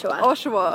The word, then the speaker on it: Oshawa: So you're born in, how do you say it, Oshawa or? – Oshawa. Oshawa, yes. Mm-hmm.